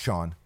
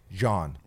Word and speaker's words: Jean: Or not Sean, John.